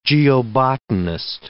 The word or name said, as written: geobotanist